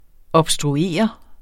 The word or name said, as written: obstruere